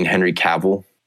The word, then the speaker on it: Cavill: Well, you mean Henry Cavill? – Oh, yeah. – Henry Cavill. Cavill? – I did say that wrong. – Yeah, Cavill runs with travel.